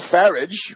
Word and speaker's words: Farage: So therefore, at that level, Nigel Farage, rhymes with you know what, was the guy who pushed this entire thing on Cameron.